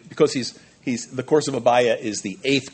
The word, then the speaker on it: Abijah: of the course of Abiah, or Abijah, if you want to pronounce it that way, Abiah.